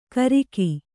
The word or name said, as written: ಗರ್ಗ